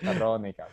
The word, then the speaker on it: Weronika: And the way they spell it is W is pronounced V, like here. Oh. So she spelled like W-E-R-O-N-I-K-A.